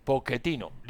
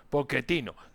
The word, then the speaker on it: Pochettino: Pochettino. Listo, Pochettino.